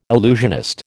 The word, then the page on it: illusionist: American English